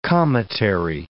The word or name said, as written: cometary